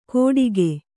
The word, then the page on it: ಕೊಟಿಗೆ: koṭige